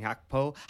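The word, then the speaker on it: Gakpo: I know you haven't been hearing much of that lately, but both the new boys, Darwin and Hakpo, I'm told it's pronounced, is, yeah, great to see both of them get some goals in the league.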